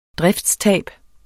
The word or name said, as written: driftstab